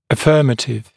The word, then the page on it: affirmative: ə’fɜːmətɪv; э’фё:мэтив